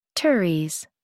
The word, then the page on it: teres: American English